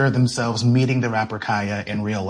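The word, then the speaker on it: Khia: I am going to, in this conversation, pronounce Kaya, the rapper, with the long I. Kaya, okay?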